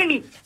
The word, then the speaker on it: Amy: Oui, bonjour Julien, Marie Amy. – Amy, alors, ça s'écrit A-M-Y ? – Oui, à l'anglaise.